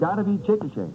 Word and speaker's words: Chickasha: C-H-I-C-K-A-S-H-A. I said Chickasha. – Oh, Mr. McNeil. – That is not right, I've taken. No. But the name is pronounced Chickasha.